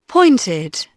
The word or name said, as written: pointed